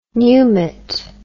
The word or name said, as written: pneumat